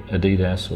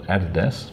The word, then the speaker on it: Adidas: Earlier this week, I was down at the Adidas, or for people who call it Adidas, there are two pronunciations. – The German pronunciation, yeah.